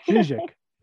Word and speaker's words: Chizhik: Chizhik.